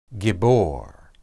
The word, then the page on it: gibbor: Hebrew